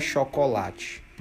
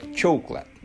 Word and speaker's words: chocolate: A pronúncia não é chocolate. A pronúncia é chocolate. Chocolate. Chocolate. Percebam a diferença de chocolate para chocolate.